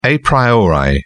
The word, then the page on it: a priori: British English